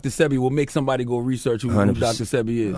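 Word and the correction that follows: Sebi: CB. My bad, I said it wrong.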